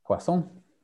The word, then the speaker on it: poisson: Can you say pois? – Pois. – Pois. Okay, no problem. Pois, toi, pois. Okay. Now, okay, in Spanish, how do you say sound? – Sonido. – Sonido. Pois, and now say pois and sonido. – Pois, sonido. – Poisson. – Poisson. – No. – Poisson. – Poisson. Second time. Poisson-nido. – Poisson. – Son-nido. – Poisson. – Poisson. Sonido. Okay, sound. Sonido. Poisson. Okay, poisson.